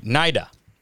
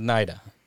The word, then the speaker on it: Naida: All right, Naida, right? We're going with Naida. That's the correct pronunciation.